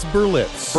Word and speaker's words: Berlitz: Also, a review of the 2013 Blitz, how do you say that? – It's Berlitz. – Berlitz, yes.